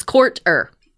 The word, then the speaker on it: Courter: It's by Ashley Rhodes Courter, it's Courter, like it's how it's spelled.